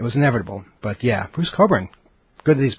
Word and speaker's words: Cockburn: Back in the 80s, everybody knew it was Bruce Cockburn, not Cockburn, Cockburn, because there was a whole big PR campaign to pronounce it right.